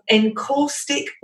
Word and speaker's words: encaustic: encaustic wax.